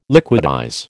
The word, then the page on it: liquidize: American English